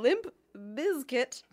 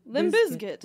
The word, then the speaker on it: Limp Bizkit: I've never heard anyone pronounce it with the Z. That's so funny that I'm thinking about that now. – Limp Bizkit.